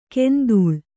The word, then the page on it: ಕೆಂಧೂಳ್: kendhūḷ